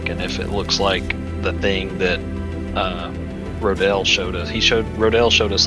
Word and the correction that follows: Rondel: Are you saying Rodel? – Didn't you say his name was Rodel? – Rondel, R-O-N.